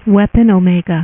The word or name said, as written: weapon omega